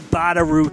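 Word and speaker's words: Badarou: This is a Wally Badab. I never say his name. Hold on, let me try to pronounce it. Wally Badaru track.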